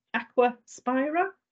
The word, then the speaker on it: Aquaspira: So, you are from Aquaspira. Am I saying that right? – That's correct, yeah.